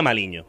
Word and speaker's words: Maligno: Mi apellido se escribe maligno, hmm. pero se pronuncia maliño.